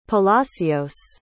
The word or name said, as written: palacios